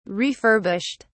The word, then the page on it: refurbished: British English